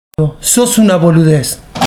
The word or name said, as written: Boludez